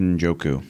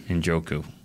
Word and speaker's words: Njoku: And and I'll uh, let you say his name because this one that I always butcher David Njoku. Njoku.